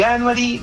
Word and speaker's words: Veganuary: Veganuary, Veganuary. – Ya, ok. – Hay muchas formas, todos se complican, pero mientras se entienda la esencia, es la idea. – Ya, voy con Veganuary Latam entonces. Veganuary, así.